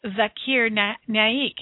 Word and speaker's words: Zakir Naik: Zakir Naik.